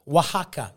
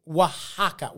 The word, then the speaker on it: Oaxaca: Oaxaca. Okay, I'm going to spell it for you. Because the letter X in Mexico is the sound of H. That's where H came from. So it's O-A-X-A-C-A. Oaxaca.